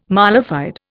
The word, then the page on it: mala fide: Indian